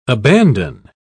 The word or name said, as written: abandon